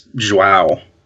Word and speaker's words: João: It's Joao. – Yeah. So it's J-O-A-O.